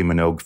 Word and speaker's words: Minogue: I've been a huge Kylie Minogue fan since the start of her career. – I'm so glad you pronounced it with two syllables.